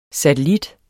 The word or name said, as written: satellit